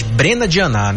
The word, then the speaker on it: Brena Dianá: A parintinense Bruna Dianá foi empossada como deputada estadual. Ela ocupa a vaga deixada por Roberto Cidade, hoje governador do Amazonas. Aliás, Brena Dianá, né? Pronunciei errado aqui.